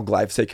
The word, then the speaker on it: glyphosate: And now glyphosate can be sprayed freely on these crops and they won't die.